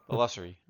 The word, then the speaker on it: illusory: He killed three, but that squad was illusionaried. Is that what we would be correct? – Yeah. – In the Patreon episode, we went over this, and it turns out I was mispronouncing it. Illusory.